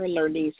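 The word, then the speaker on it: Lernise: I'm on Facebook under Keever Lernice Murdaugh, and my middle name is spelled L-E-R-N as in Nancy, I-S-E.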